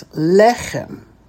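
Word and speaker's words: lechem (לחם): Now, normally the accent would be where? That's right. It would be on the last syllable. However, there are two segols in a row. So what happens? The first segol gets the accent. So that's le, and then the last syllable is chem. So that's lechem.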